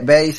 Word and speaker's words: bass: Pasa que bass se pronuncia bass, pero se escribe con doble S raro. Yo solo tuve que aprender en su momento que, aunque tiene doble S y uno lo pronunciaría como si fuera ads, así como si dijera, bueno, trasero, ¿no? Ahí, pero digo, se pronuncia bass, es rara esa palabra, Bass Persuades.